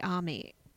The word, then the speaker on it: Amy: When I was doing my research, I found this woman called Sherry Army. It's spelled Amy, but she says Army.